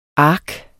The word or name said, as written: ark